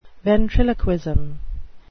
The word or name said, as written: ventriloquism